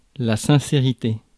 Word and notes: sincérité: France; [sɛ̃.se.ʁi.te]

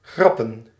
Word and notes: plaisanter: France; /plɛ.zɑ̃.te/

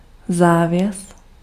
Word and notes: rideau: France; [ʁi.do]

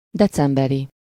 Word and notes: decemberi: /ˈdɛt͡sɛmbɛri/

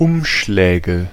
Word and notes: Umschläge: /ˈʊmʃlɛːɡə/

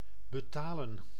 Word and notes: betalen: [bə.ta.ləː]; /bə.ˈtaː.lə(n)/

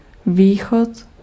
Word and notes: východ: [vɪːxɔt]; /ˈviː.xɔt/

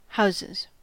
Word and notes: houses: English

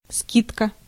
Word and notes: скидка: /ˈskʲɪtkə/; skidka

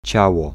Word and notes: field: [fild]; /fiːld/

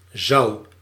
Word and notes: zou: IPA: /zɑu̯/

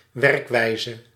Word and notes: werkwijze: /ˈʋɛrk.ʋɛi̯.zə/

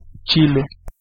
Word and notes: chile: /ˈtʃi.le/

